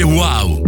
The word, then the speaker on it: uau: Si scrive UAU, si legge wow.